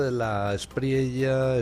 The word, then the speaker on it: De la Espriella: Abelardo de la Espriella está... Por cierto, ¿cómo se pronuncia don Guillermo? ¿De la Espriella? – De la Espriella.